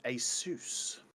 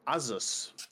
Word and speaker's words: ASUS: They have partnered up with ASUS, or ASUS, however you would like to pronounce it.